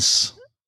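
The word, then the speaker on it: SenX: Donc, Warp 10, c'est une Time Series DB développée par Senix. C'est comme ça que ça se dit. – Ça se dit Sense.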